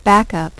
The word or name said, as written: back up